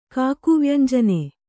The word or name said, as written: ಕಾಕುವ್ಯಂಜನೆ